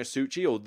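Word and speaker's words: Suchý: I'm not typically a massive fan of his designs, Vladimir Suhi, apparently, that's how you pronounce it. You apparently pronounce it Suhi. You don't pronounce it with a chi, like CH or anything.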